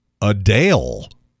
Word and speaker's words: Adele: She now says that her name is supposed to be pronounced Adele. Or Hodale. That's what she says.